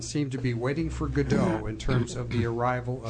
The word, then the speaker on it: Godot: First of all, there's a famous play, Waiting for Godot, although it's spelled G-O-D-O-T, by Samuel Beckett.